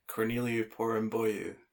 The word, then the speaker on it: Corneliu Porumboiu: Corneliu Porumboiu.